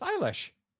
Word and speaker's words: Eilish: It's Eilish.